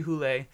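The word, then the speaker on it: Hole: But it was Joe Nesbo's mysteries, the Harry Hule mysteries, and I had to ask a Norwegian student how to pronounce the detective's name, but it's Harry Hule, and they're just these, like, real grisly, dark Norwegian crime stories.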